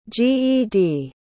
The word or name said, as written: ged